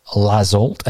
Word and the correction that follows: Laxalt: and he's saying that it's Laxalt, according to our Spanish correspondent.